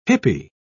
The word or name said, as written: hippie